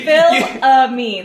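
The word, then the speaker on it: Fils-Aimé: It's Fils Ami.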